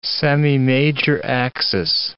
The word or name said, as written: semimajor axis